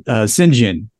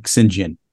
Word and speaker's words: St. John: And his brother, Sinjin, which is apparently St. John. – St. John, yeah. – But they say it like Sinjin.